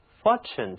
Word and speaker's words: fortune: 运气不同于我们常用的 luck，我们这里呢用了 fortune 这个词，它表示的是运气、命运、财产。其中这个字母 T 读作，大家把舌尖抵住上牙床，向外吐气，就可以发出这个音来了。